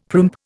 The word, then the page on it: primp: Indian